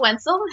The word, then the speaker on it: Wenzel: So my name is April Wenzel. It happens to rhyme with pencil.